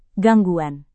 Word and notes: gangguan: Indonesian

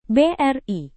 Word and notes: bri: Indonesian

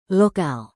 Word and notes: lokal: indonesian

